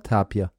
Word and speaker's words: Tapir: Heißt es Tapir oder Tapir? – Tapir. – Das ist die Betonung auf dem I? – Ich habe jetzt nochmal anders betont. Tapir.